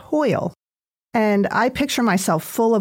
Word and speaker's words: hwyl: It's hwyl, pronounced like oil with an H, hwyl, but it's spelled H-W-Y-L.